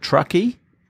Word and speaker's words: Truckee: Is it Truckee? – It is. Yeah, just yep. Truck with two E's.